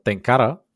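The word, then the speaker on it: tenkara: Какъв риболов предпочитате обикновен, мухарски е, тенкара, това е японска дума, та ударението е на последното А, а на лед или Big Game, може би с копие.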